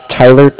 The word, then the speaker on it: Tyler: My name is Tyler, T-Y-L-E-R. And I'm calling with Cardiff. That's spelled C-A-R-D-I-F-F, as in Frank.